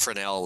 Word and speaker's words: Fresnel: It actually turns out it's pronounced Fresnel lens, and because there's a city of Quesnel in British Columbia, and I figured it had to rhyme with that.